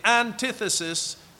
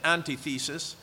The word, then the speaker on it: antithesis: The Oxford English Dictionary has this definition, the antithesis of good. I don't know how many years I went around pronouncing it antithesis, but it's antithesis. The antithesis, but it's pronounced antithesis, of good in all its principal senses.